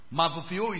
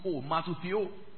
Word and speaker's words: matheteuo: Matuphio is spelled M-A-T-H-E-T-E-U-O, matuphio.